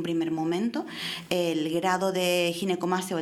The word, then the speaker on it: ginecomastia: Doctores, ¿qué grado de satisfacción se produce en los en las personas que te deciden hacerse una ginecomastia? ¿Lo he dicho bien? Ginecomastia. – Ginecomastia. Sí, lo has dicho bien.